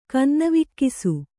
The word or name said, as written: ಕನ್ನವಿಕ್ಕಿಸು